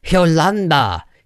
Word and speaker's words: Yolanda: Her name rhymes with Rwanda. – Kate Beckinsale? – It is Yolanda.